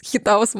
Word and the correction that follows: хиатус: Мы так и не запомнили это чертово ударение. Хиатус.